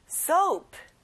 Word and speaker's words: soap: 친구들, soap가 아니라 가운데 O 발음을 내보세요. soap. 잘했어요.